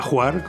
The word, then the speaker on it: Hvar: Otra imprescindible y que me encanta se llama Juar, aunque se escribe H-V-A-R, pero se pronuncia Juar.